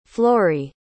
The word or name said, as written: florrie